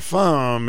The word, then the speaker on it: Pham: Pham is his name.